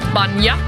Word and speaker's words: bainne: Och det skrivs F-I-R-I-N-N-E. – Ja. – Och sista ordet som jag förstår man uttalar som typ Banja, som skrivs B-A-I-N-N-E. – B-A-I-N-N-E.